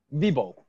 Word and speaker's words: Vibol: Vidlo, am I saying his name correctly? Vidlo? – Vibo. – Oh. – V-I-B-O-L, yeah. – Oh, Vibo. I said it wrong today.